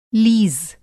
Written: लीज़

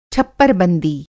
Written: छप्परबंदी

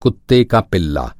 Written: पिल्ला

